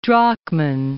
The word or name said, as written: Drachmann